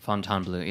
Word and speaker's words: Fontainebleau: Is that how you pronounce that? Fontainebleau? – Fontainebleau, yes.